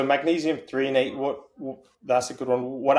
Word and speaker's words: threonate: Threonate? – Yeah.